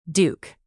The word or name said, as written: duke